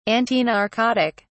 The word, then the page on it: antinarcotic: British English